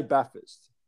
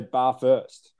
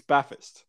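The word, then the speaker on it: Belfast: We say Baffist. But people who don't grow up there say bar first because that's how it's spelled. – Yeah, they do. I'll give you that one. It's super frustrating. – Yeah, it is super frustrating. So to everybody out there, it's Baffist.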